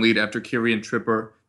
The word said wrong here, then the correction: Trippier: Trippier? Tri- ah, man, pronunciation's not my strong suit today. Uh, but Kieran Trippier conceded a penalty uh, in the first 10 minutes of that match, which, which was not something that you wanted to see.